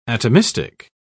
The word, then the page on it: atomistic: British English